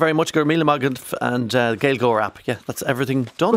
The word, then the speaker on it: Gaelgoer: It's Gael Goer, but it's spelled in the English way, G-A-E-L-G-O-E-R.